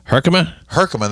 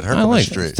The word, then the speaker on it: Herkimer: Herkimer, that's how you pronounce that. Herkimer Street.